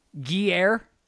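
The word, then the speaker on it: Gruyère: One pound of gear cheese. – Do you understand what you just did? No. Gruyere cheese.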